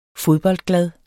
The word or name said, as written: fodboldglad